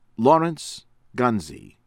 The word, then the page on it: Lawrence Gonze: American English; from Malta